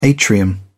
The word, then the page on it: atrium: British English